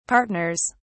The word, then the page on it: partners: British English